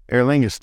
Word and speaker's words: Aer Lingus: Aer Lingus through Dublin.